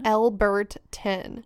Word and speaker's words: Elberton: Alberton or Elberton? – Elberton. – Elberton. – Elberton. I'm putting too much emphasis on like every syllable, so it sounds like Alberton, but it's Elberton.